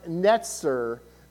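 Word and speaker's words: netzer (נצר): Well, in Hebrew, the term branch, it's pronounced netzer, but when in Hebrew, when you write words, you leave out the consonants.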